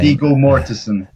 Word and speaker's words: Viggo Mortensen: Viggo Mortensen.